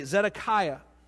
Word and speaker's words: Zedekiah: There was a man there named Zed- uh, let me say it correctly, Zedekiah.